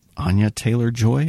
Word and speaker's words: Anya Taylor-Joy: starring Olivia Cooke and Anya Taylor-Joy.